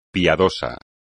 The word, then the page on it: piadoso: Spanish